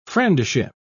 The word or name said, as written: friendship